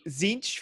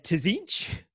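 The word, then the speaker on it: Tzeentch: Zinch for me, I as a kid, I didn't know not to pronounce the T, so I said Tzinch all the time.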